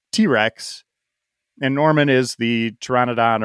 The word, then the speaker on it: pteranodon: It's P-T-E-R, I believe. Yeah. So, you know, the pteranodon, the P is silent.